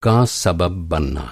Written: सबब

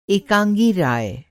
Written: एकाँगी

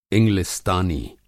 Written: इंगलिस्तानी

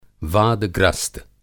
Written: वादग्रस्त

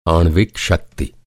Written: आणविक

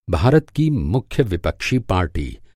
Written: पार्टी

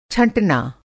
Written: छँटना

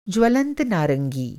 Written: ज्वलंत

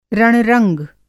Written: रण-रंग

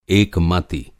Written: एकमति

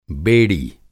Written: बेड़ी

